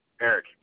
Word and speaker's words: Eric: Eric or Garrett? – Uh-huh. Eric, E-R-I-C. – Reeves is spelled R-E-E-V-E-S? – No, R-E-E-C-E.